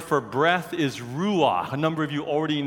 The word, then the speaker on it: ruach: It's two syllables, ruach. Say ru, say ah. It's in the back of your throat. Do a ch. Go ch. Ruach.